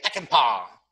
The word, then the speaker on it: Peckinpah: So, yes, that's how you say it, Peckinpah.